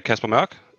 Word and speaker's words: Caspar Mørch: My name is Caspar Mørch.